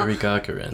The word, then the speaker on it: Yuri Gagarin: He went to space before Yuri Gagarin. I can't say his name very well. – Yuri Gagarin. – Yeah, that's the name.